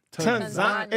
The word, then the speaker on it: Tanzania: So the A is pronounced like a U. – Tanzania. – Tanzania. – So both A's are like long.